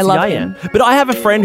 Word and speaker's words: Ieuan: He spells his name I-E-U-A-N. – Is it pronounced Ewan? – I thought it was Ewan, and then I got berated because it's actually Yian.